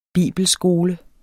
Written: bibelskole

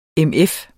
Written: MF

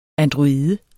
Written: android

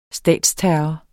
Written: statsterror

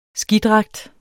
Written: skidragt

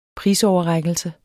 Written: prisoverrækkelse

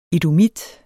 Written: edomit

